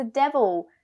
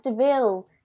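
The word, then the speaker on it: De Vil: It's like Horace has a car and it's like, it's a devil. And then Jasper's like, it's pronounced Deville. And the camera turns to Cruella and she goes, Deville.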